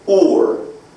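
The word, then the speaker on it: or (עור): Well, actually, this word skin is the word or. O-O-R.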